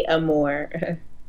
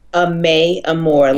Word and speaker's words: Amour: Amour. – Say it one more time. – Amour, like love in French.